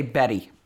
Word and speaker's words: Betty: See, in the U.S. we'd say Betty. – Betty. – Almost as if it was spelled with two D's. – Yeah. – But they make a point of doing hard T's.